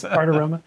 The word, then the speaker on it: Bartiromo: Bartiromo. – Say that Bartiromo, three times. – Bartiromo.